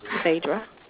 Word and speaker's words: Phaidra: Phaedra. – Phaedra? How do you spell that? – P-H-A-I-D-R-A.